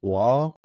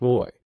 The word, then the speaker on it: Roy: Putting in Patrick Law, or as some of us call him, Patrick Roy, because it is spelled Roy, we are not Canucks. Therefore, it is Patrick Roy.